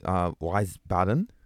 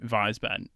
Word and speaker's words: Wiesbaden: Maybe Wiesbaden. I think W in Germany is a V.